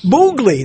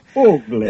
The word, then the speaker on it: Bugliosi: Did I say it right again? – Bugliosi. – That's right. It's a boog, not a big. – Bugliosi.